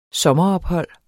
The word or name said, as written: sommerophold